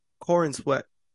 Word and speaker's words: Corenswet: Corenswet.